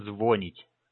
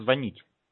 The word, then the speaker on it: звонить: Вот смотри, некоторые говорят, вот большинство россиян почему-то говорят звонить. Это ж неправильно тоже. Надо звонить.